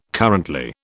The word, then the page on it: currently: British English